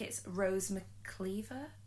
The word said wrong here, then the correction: McIver: Cliver. She's the. – Oh, from Game of Thrones again. – No, she's in iZombie. – Oh, yeah. Rose McIver.